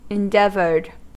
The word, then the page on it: endeavored: American English